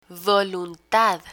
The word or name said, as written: voluntad